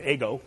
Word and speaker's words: ego: Except in Latin you pronounce it ego, but like the waffle.